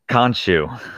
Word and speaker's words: Khonshu: The God Khonshu.